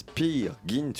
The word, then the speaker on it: Peer Gynt: On parlera également de Pyrgine, une mise Pyrgine Gint, euh, pardon. Pourtant, je l'avais bien prononcé tout à l'heure, et là j'ai refait la bêtise. Pire, Gint, une mise en scène d'Irina Brooke, d'après l'œuvre éponyme d'Henrik Ibsen, présentée au Théâtre des Bouffes du Nord jusqu'au 18 février.